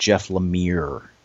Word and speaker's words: Lemire: We did get a correction on the blog that it's pronounced Jeff Lemire.